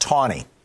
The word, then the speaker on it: Chaney: Except, by the way, his name was pronounced Tawny. There was no W, but that's how they pronounced it.